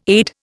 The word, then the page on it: AID: Indian